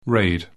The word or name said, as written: raid